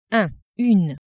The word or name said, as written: un / une